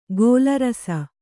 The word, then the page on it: ಗೋಲರಸ: Kannada